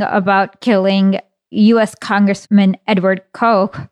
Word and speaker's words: Koch: Yeah, Koch.